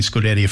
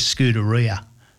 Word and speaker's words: Scuderia: Unlike old mate Lazenby that does the Formula One commentary and can't pronounce Scuderia, well, as in Scuderia Ferrari, he says Scuderia.